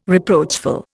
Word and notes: reproachful: Indian

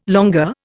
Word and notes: longueur: Indian

